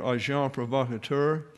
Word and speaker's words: agent provocateur: Now, this term here, agent provocateur, that's the way it's pronounced, and I gave you the pronunciation to the right there. Agent provocateur.